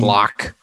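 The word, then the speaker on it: Glück: So I can't pronounce Louise Gluck. I don't know how to pronounce this. Louise Glock. – Glock. Louise like Glock. Like the weapon.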